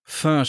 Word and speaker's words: Pho: Pho spricht man das aus. Pho? Du meinst, du weißt die vietnamesische Suppe. Pho. Ja, das wird wie Pho, ausgesprochen. Pho ausgesprochen. Wie das Parfum, ja.